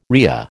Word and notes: rhea: American English

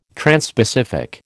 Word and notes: transpacific: American English

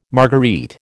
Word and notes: marguerite: American English

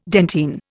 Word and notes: dentine: Indian

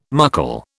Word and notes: muckle: British English

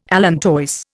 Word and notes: allantois: Indian

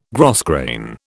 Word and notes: grosgrain: British English

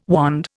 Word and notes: wand: Indian

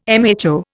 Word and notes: mho: Indian

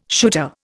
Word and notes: shooter: Indian

